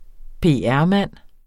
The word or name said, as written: PR-mand